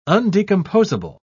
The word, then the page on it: undecomposable: British English